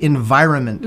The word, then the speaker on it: environment: So the first thing I want you to think about, the first tip is it the right environment mm-hmm. for your dog? – Maybe environment. Can, yeah, you maybe, really pronounce that word. – It's, yes, absolutely. – Most people don't pronounce the N in that word.